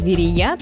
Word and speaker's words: Верея: Что наши предки имели в виду, когда называли город Верея, точно неизвестно. Однако ударение в этом слове только на последний слог. Верея.